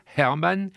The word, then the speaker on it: Hermann: Donc le Hermann va devenir Hermann. – Hermann, il y a le H.